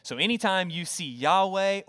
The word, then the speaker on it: Yahweh: Which we would then transliterate and pronounce in English as we sung this morning, Yahweh, okay?